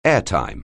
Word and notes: airtime: English

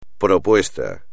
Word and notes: propuesta: espagnol

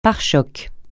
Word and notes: pare-chocs: francais

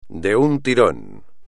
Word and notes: de un tirón: espagnol

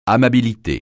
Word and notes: amabilité: francais

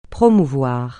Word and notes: promouvoir: francais

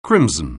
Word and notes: crimson: anglais

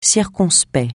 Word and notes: circonspect: français

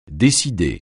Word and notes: décider: French

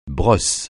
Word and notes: brosse: francais

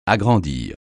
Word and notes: agrandir: French

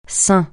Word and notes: sein: francais

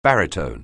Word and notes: baritone: anglais

